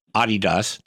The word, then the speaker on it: Adidas: what is it like if you're a tour player and you guys have Adidas? Did I say it right that time? – You did. Good job there.